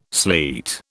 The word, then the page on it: sleet: British English